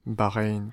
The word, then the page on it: Bahrain: American English; /bɑːˈɹeɪn/